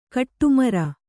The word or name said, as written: ಗಡಿಮೀರು